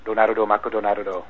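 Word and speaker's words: Donarudo Mako Donarudo (ドナルド・マクドナルド): I guess because when you pronounce it phonetically, it would be Donarudo. And if you were going to do the whole, it would be Donarudo Mako Donarudo, which is just way too many syllables. It becomes a little difficult to say.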